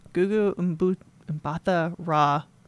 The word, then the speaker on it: Gugu Mbatha-Raw: So this actress, Gugu Mbatha-Raw, which I wanted to make sure I said that right.